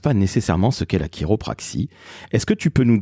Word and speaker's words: chiropraxie: Alors la chiropraxie, donc on dit chiropraxie, mais en effet ça s'écrit C-H-I-R-I.